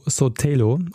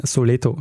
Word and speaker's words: Sotelo: Und äh, der heißt Leopoldo Calvo Sotelo. Und ich sage einmal Soleto statt Sotelo.